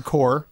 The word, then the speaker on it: Corps: I guess technically I shouldn't pronounce the P. Contra Rogue Corps, right? – Yeah. – P is silence. – That is correct.